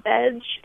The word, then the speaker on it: Vedge: is a place called Veg in Philadelphia. I think it's about 2 years running now. It's relatively new, and it's spelled V-E-D-G-E, and it's right in the city.